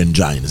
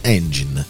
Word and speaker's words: engine: ma ragazzi non si dice engine, si dice engine.